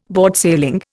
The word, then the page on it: boardsailing: Indian